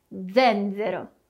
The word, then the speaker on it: zenzero: O primeiro e o segundo Z tem um som de abelhinha aqui, né? Então zen e de novo zero. Zenzero. Vamos ver outro exemplo. Zafferano, zafferano, que é açafrão. De modo geral, podemos usar essa pronúncia de abelhinha, com as palavras que começam com a letra Z. Assim como a gente viu em zenzero e zafferano.